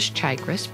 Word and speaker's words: Chagres: One of the most unusual names was Chagres, pronounced with a long A, and we still don't know where this name came from.